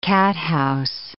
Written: cathouse

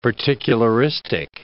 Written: particularistic

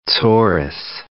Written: tsoris

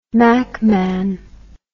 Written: mackman

